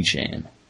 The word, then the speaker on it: Chan: It's either Chow or Chan, I'm not sure. And I think he changed his name from time to time. – Ernie Chan.